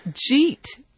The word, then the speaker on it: jeet: Jeet. Now, if we were to pronounce that, you know, the way it's written, we would say, did you eat yet? But in rapid spoken English, the did you gets reduced.